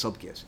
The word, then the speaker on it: Sulpicius: Wasn't a C pronounced like a K back sure. then? – Sulpicius? Yeah, I think you're right. I think it's Sulpicius.